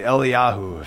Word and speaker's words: Eliyahu: We did Elijah with a soft J instead of Elijah with a hard J, and we did that because in Hebrew they don't have a hard J. The Hebrew name is actually Eliyahu, if you're to do it in Hebrew, and the name means the Lord is my God.